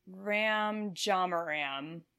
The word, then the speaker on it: Ram Jamaram: Is it Ram or Rom? – Ram Jamaram is his, or Ram Jam. Yeah, Ram. Ram Jamaram. His name kind of rhymes.